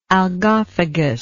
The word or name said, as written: algophagous